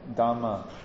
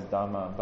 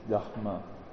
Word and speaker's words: Dharma: So for example, Dharma, we usually pronounce as Dharma, but the more correct pronunciation is Dharma. Dharma, yeah.